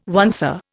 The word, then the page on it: oncer: Indian